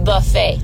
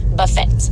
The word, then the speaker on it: buffet: And when you pronounce the T in buffet, buffet.